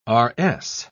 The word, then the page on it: rs: British English